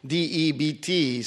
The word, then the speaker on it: debt: D E B T is pronounced debt, but not debt, right? And I'm here, like, okay, honey, how come they don't pronounce the B? What's wrong with the B? So it's debt. Christian debt.